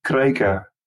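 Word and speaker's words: Kreikka: With a hard K, Kreika.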